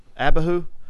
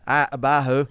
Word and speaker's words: Abihu: Abihu. There we go. Abihu. – I have to look at it. – Oh, gosh. Y'all are getting back into these Hebrew words. Abihu.